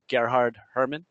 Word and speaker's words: Gerhard Hoermann: Nobody pronounces my name right. It's actually Gerhard Hoermann.